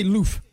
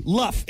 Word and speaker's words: LUF: L-U-F-F? – No, just L-U-F. L-U-F. – L-U-F. – Yeah. Germans might say loof. – Loof? – it depends on if it's, if it's a hard U or a long U. Luff. It's an acronym.